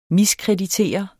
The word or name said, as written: MISKREDITEREDE